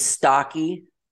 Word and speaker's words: Staake: And today I have the honor of interviewing what I call a true American hero, a fighter, James Staake, like short and stocky, as he likes to say it so people pronounce it.